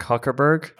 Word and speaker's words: Zuckerberg: It's pronounced Mark Huckerberg. – Ah, okay. – Z is silent.